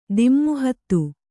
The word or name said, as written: ದಮ್ಮುಹತ್ತು